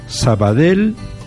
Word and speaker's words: Sabadell: ha de escribirse obligatoriamente con doble L pero se pronunciará siempre una L simple. Sabadell, Vendrel Martorell.